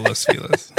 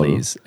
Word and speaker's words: Los Feliz: It's regionally pronounced Los Feliz. – So yes, it's regionally correct. Pronounced Los Feliz, even though in Spanish, in it's Espanol, Los Feliz.